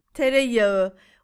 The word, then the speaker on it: Tereyağı: Tereyağı. We don't read it. It's a silent letter, Justin. – Right. – Tereyağı. – But it makes that A a little longer, doesn't yes.